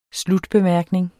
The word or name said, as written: slutbemærkning